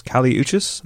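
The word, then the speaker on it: Kali Uchis: I've been listening to Isolation, which is a debut album by Colombian-American artist Cali Uchis, and that's how it's pronounced.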